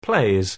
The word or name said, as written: plays